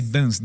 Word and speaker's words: Daens: Padre Dance, Daens, se escreve, né?